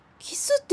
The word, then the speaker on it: KYS: ベロニカさん、ようこそ僕のレストランキスへ。 – キス？ KYS ってキスって読むのか。